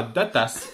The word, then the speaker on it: datas: Donc, ils annoncent l'ouverture d'un bar à datas. Alors, ça s'écrit avec un S, mais ça se dit comme les tapas. – Oui, bien sûr. – Attention, la prononciation se fait à l'espagnol, bien sûr.